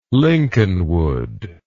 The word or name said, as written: lincolnwood